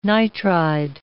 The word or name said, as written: nitride